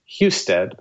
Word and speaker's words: Husted: This is Philip Randolph Institute versus, I think it's pronounced Husted, but you know, Husted.